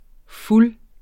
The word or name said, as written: fulde